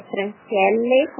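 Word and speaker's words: Francielle: É Franciele com dois L's e um E.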